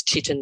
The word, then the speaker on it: chitin: It's called chitin, C-H-I-T-I-N. I don't know if it's chitin or chitin. – Not chitlin. – It's the American pronunciation is chitin.